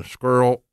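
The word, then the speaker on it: Scurll: Main event, Aldis and Squirrel, which rhymes with the girl, Marty Squirrel, will be fighting for the NWA heavyweight title.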